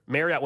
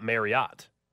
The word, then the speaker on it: Marriott: This says Travel and Leisure, Marriott, when pronounced correctly, should rhyme with chariot, not Marriott.